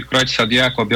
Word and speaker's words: Creutzfeldt-Jakob: É a doença, vou ver se estou acertando a pronúncia, é Creutzfeldt-Jakob. Nós vamos conversar com o neurologista e neurocirurgião. Muito boa noite. – Boa noite. – É essa mesma a pronúncia? Pra gente... – É, Creutzfeldt-Jakob, tanto faz.